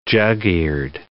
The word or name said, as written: jug-eared